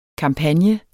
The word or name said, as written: kampagne